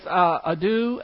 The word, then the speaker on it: ado: It's ado, A-D-O. Ado, that's a long O.